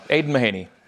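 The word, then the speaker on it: Aidan Mahaney: And then this year, the young freshman, Aiden McEhaney, I said the name wrong. – Aiden Mahaney. – There we go. I gave him an extra syllable, which is...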